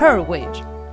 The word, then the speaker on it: Hurwitz: Hurwitz. Hurwitz. – Hurwitz. Hurwitz. Ας το πούμε με μία προφορά. – Justin Hurwitz Ωραία το είπε.